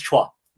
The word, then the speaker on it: Chua: Yeah, so very, very excited to bring Fong on the show today and Feng Shui, right? Did I did I say that right? It's Chua. – It's all good. Chua. Chua. I said Shui.